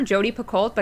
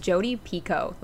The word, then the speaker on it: Jodi Picoult: So I, a couple weeks ago, bought a ticket to Breathe, a new musical, which is written by, we've always called her Jodi Picoult, but guys, it's Jodi Picoult. Like, that's how she pronounces her own name.